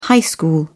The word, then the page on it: high school: British English